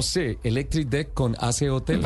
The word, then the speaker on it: AC Hotel: Hace hotel, está bien, aquí decimos todos, hace hotel.